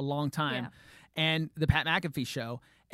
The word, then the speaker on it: McAfee: I always say it wrong with my accent. McAfee.